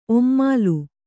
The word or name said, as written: ಒಮ್ಮಾಲು